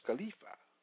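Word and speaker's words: khalifa (חליפה): The Hebrew word for change in this text of scripture is chalipa, pronounced khalifa, meaning a change of garments, replacements, relays, relief from death, or changing, varying course of life.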